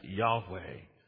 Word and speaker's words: Yahweh: We pronounce it as Yahweh.